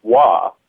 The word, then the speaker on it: oie: So, being somebody who speaks a little bit of French, I figured that Wawa, in French, the word for goose is wa. It's spelled O-I-E, but it's pronounced wa.